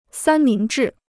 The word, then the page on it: 三明治: sān míng zhì